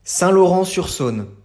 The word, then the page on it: Saint-Laurent-sur-Saône: French